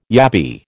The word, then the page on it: yabby: American English